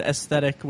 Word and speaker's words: aesthetic: About a few months ago, I ran into a situation where I realized that I was told very politely that I've pronounce the word aesthetic with a hard AE, so it's like I've been pronouncing aesthetic as aesthetic for years.